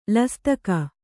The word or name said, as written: ಲಸ್ತಕಿ